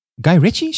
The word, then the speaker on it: Guy Ritchie: Het is door Guy Ritchie, zeg ik dat goed? – Ja, dat zeg je goed.